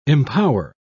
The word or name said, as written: empower